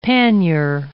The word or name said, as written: panier